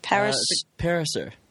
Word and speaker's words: Pariser: Pariser. – Pariser? I always want to add an extra.